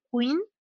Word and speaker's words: Quin: Eh, en las recomendaciones de libros, digo, aparte del Bloody Henderson, hay uno que se llama Microbiología Veterinaria de Queen, se escribe Q-U-I-N, eh, que digo es típico, en todas las facultades está.